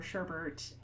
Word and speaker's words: sherbet: So he was, for that clue, you really need to correctly, you need to pronounce sherbet in that way in order to get the same last three letters as the word sorbet. – Yeah. – in this case, it was asking for sherbet or sherbert, but I think because there wasn't that spelling specification, they could be a little flexible about regional pronunciations.